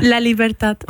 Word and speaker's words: La Libertad: La Libertad.